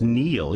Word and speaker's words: Niall: I think you actually say it's pronounced Neil, even though it's spelt Niall. – Okay. – Because he's Irish.